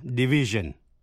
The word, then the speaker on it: division: Những danh từ tương đương là division, chữ S đọc là J.